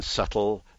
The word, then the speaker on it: subtle: The silent P in receipt, the silent B in subtle, the silent L in salmon, and so on.